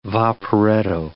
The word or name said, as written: vaporetto